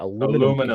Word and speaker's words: aluminium: Aluminium debate's coming aluminium. – On. – Aluminium is aluminium. You just call it aluminum.